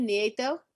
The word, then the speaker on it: Nieto: Am I saying your name properly, Nieto? – Yes, it is. Thank you.